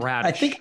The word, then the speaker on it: Bradish: I feel like you're the one who mixes us up, with all due respect, because I think we get it right, and then we just make the joke like, oh, it's Bradish. Oh, it's okay. Back it up. Bradish. That is his baseball reference pronunciation note.